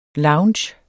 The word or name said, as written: lounge